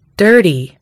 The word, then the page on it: dirty: American English